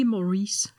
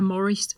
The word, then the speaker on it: Morris: Ja, og det første det er, at det er ikke Maurice. – Undskyld. Det er Morris. Morris, ja. – øh, det, Han skal udtales på engelsk, og det er også en, en, øh, en engelsk bog, selvom han er stavet på den franske ja.